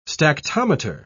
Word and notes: stactometer: British English

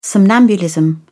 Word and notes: somnambulism: British English

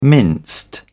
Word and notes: minced: British English

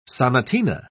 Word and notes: sonatina: British English

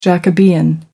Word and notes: jacobean: American English

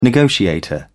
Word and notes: negotiator: British English

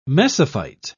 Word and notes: mesophyte: British English

